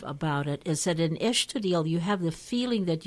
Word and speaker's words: Estoril: So that's one of the things that you Well, I'm corrected already because I called it Estoril. The S again pronounced Estoril. Estoril. – You know, Portuguese have that sound in their language.